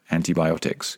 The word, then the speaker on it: antibiotics: Antibiotics, which is a very interesting word because there are three I vowels in that word and they are all pronounced differently.